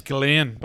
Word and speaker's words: Ghislaine: Oh, her name's Ghislaine. – Oh, what's they call her? Ghislaine, okay. – Jazelle, but you think the mix-up is because the way it's spelled, some people say Ghislaine, but you didn't do that either. No. You called her Jazelle.